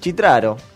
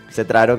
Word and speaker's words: Cetraro: En italiano sí, claro. Pero acá en argentino es Cetraro, que es claro. Como se lee.